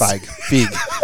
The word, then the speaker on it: Feig: Feig.